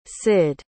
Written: cid

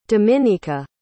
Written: dominika

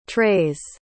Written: trays

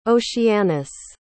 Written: oceanus

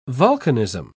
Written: volcanism

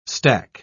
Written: stack

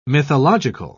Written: mythological

